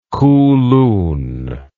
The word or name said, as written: kulun